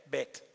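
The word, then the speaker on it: Beth (בית): It's spelled like Beth, but we want to be a little more Hebrew when we say it, so it's Beth, Beth.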